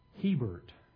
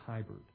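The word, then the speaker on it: Hiebert: I've been told that you pronounce the last name Hebert. I've always pronounced it Hybert.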